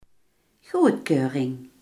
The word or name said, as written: goedkeuring